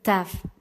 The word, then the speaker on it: Taf: La última letra es la letra Taf, que escrita se dice la letra Tau y esta se pronuncia mejor así.